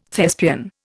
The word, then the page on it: Thespian: Indian